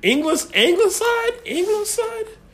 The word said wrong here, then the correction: Ingleside: I mean, how do you pronounce that bullshit? – Ingleside. – Ingleside district? Ingleside. What type of bullshit name is that for a city?